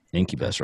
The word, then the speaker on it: Incubus: I keep saying that wrong. Incubus. – That's right. – Incubus, right? – Oh, Incubus. – Incubus. – Like incubator.